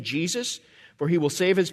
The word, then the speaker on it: Jesus: It's exactly the same Hebrew word as the Old Testament name Joshua, but it's been transliterated into Greek in the New Testament, and it becomes Iesus. And we use the anglicized pronunciation, Jesus.